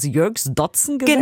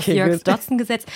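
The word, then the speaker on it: Yerkes-Dodson-Gesetz: Das Jörgs-Dotzen-Gesetz? – Genauso spricht man es aus. Genauso spricht man auch. – Jörgs-Dotzen-Gesetz.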